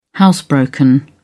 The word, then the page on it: housebroken: British English